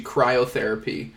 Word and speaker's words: cryotherapy: And so, first off, we said it wrong. It's actually cryotherapy.